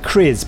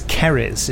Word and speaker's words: Keriz: And you said Kriz, but it's Keriz, it's pronounced, even though it's spelt Kriz, but it's pronounced Keriz because Gary Russell decided. So we kept saying, well, how is it pronounced? He said, like berries. And we said, all right, so Keriz? He goes, no, Keriz. But that's not like berries. And he said, no, but it is like berries, Keriz.